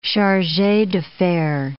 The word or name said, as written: charges d'affaires